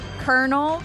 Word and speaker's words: colonel: I think I'm going to go with kernel, because it's actually spelled like colonel, but it's kernel, like a popcorn kernel.